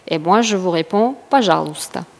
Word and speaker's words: пожалуйста: Пожалуйста. On le prononce пожалуйста.